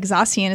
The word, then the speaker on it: Zacian: And wouldn't you know, like Zacian is kind of like cyan.